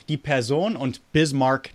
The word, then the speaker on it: Bismarck: also bitte, richtig aussprechen. Bismarck, North Dakota.